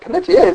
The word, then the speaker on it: Panettiere: Panettiere is how you say it.